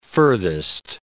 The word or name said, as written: furthest